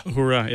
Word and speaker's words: Uhura: Uhura, it is. – Not Uhuru. I just mispronounced it.